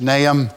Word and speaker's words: Nahum: It's not Nahum, the H is silent, it's Nahum.